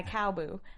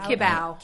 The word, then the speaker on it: Kibaou: When all of a sudden the conversation gets hijacked by Cowboo, I, I think that's how you pronounce it. – Kibaou. Kibaou. Kibaou. Kibaou. Kibaou. Yeah.